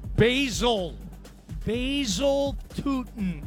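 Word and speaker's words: Bhayshul Tuten: It was a one-yard touchdown run by Vishal Tootin. Did I say it right? – Basil Tootin, I believe, is the correct pronunciation.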